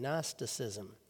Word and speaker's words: Gnosticism: It's an ancient way of thinking and religion and philosophy known as Gnosticism, spelled with a silent G at the beginning, Gnosticism.